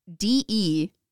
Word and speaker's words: DePauw: They resided there until his retirement in the 1950s, at which point the Becketts donated the farm to the DeWau University. DePau. – What are you pronouncing? – It's DePAUW.